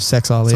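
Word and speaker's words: Suaalii: 1 wing, we got Joseph Suwali. Favorite. – Said it wrong. It's Joseph Sexali. – Sorry, Sexali. – Yeah, please pronounce it correctly.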